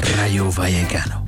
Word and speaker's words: Rayo Vallecano: En komende woensdag staat dan de Conference League finale op het programma tussen Crystal Palace en Rayo Vallecano. Had ik dat goed uitspreken? – Rayo Vallecano.